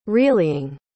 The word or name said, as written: reallying